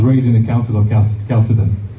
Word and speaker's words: Chalcedon: It keeps on going until the Council of Chalcedon, Chalcedon, I don't know how you pronounce it. Chalcedon, yeah? – Chalcedon.